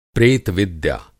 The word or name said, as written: प्रेत-विद्या